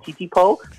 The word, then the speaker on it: Titipo: It comes from the Tayo family, and that is how you pronounce it, Titipo.